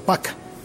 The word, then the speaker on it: Park: Se escribe Park, pero leí que se pronuncia Pak.